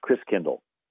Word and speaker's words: Christkindl: And the German pronunciation of Christ child is Christkindl, where you get kinder care and kindergarten. Kind means child, and Chris means Christ. And so over the years, Chris Kindle got pronounced Chris Kringle.